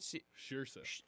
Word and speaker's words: Saoirse: Is that how you say it? – Saoirse. – Okay.